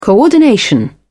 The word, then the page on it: coordination: British English